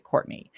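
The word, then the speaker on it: Quartney: His name is Courtney, but it's spelled with a Q. Q-U-A-R-T-N-E-Y, Courtney.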